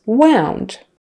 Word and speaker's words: wound: Wound versus wound. Wound is the noun, wound, with a long U kind of sound, wound.